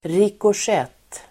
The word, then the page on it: rikoschett: [rikåsj'et:]